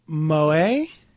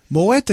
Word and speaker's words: Moët: Is it the how do you pronounce the French M-O-E-T? The French champagne. – Moe. I would say Moe. – Moe? – It's not Moe. – Okay. – Wow. – What was that? – Moët et Chandon. – So the full name is Moët et Chandon. – So it is Moët et Chandon. – Moët is the T. I heard her say the T.